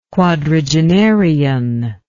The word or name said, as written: quadragenarian